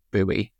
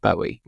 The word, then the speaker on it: Bowie: How does it compare to, like, I have an impression of Maryland in my mind because I spent the summer in Bowie, which I annoyed everybody by pronouncing it Bowie. – But Bowie, like, is right. That's how you pronounce it, actually.